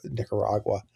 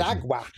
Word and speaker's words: Nicaragua: when they would say something like Nicaragua. – Oh yeah, Spanish pronunciation. – Nicaragua. – Yeah, the extreme Spanish pronunciation.